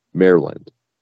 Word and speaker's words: Maryland: Pretend like it's an amusement park for mayors. Maryland.